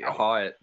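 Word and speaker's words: Hawat: I would say Hawitt. – Hawitt, that's the way that we pronounce it.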